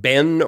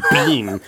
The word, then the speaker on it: been: Like B-E-E-N. Do you pronounce it Ben or Bean? – Yeah. – And I was like, who the hell pronounces it Bean?